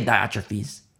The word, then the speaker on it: Diotrephes: He says, I wrote to the church, but Diotrephes, I've heard it pronounced either way. We'll say Diotrephes.